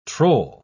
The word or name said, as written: troll